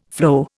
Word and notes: flow: Indian

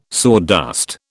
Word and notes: sawdust: British English

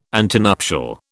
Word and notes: antenuptial: British English

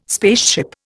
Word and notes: spaceship: Indian

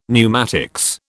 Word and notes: pneumatics: British English

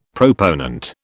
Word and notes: proponent: British English

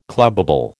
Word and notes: clubbable: American English

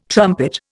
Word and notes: trumpet: Indian

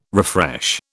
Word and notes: refresh: British English

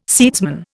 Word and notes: seedsman: Indian

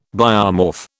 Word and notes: biomorph: British English

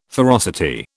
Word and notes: ferocity: British English

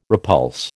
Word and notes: repulse: American English

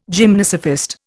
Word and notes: gymnosophist: Indian

